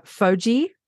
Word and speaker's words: FOGI: Chapter five, FOMO, and tell me if I say this right, Jessica, FOGI. Yep. Yep. Okay, good.